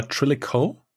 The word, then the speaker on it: Trillico: What's the right pronunciation? – Trillico, but the shorter form is definitely Trilla. So we say always like drop a man a Trilla. So Trilla. – Yeah. – Okay. – It's Trillico.